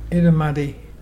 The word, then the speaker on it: Irrumardi: Our local Mulgana word today is Edamuddy.